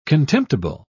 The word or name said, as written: contemptible